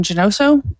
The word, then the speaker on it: Janoso: Hello, And my name is Aaron Janoso. My last name is spelled J-A-N-O-S-O.